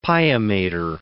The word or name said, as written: pia mater